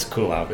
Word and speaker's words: Kulov: It's Kulaf. It's pronounced like the verb to cool off. – Oh. Cool. – But it's spelled differently. It's spelled K-U-L-O-V.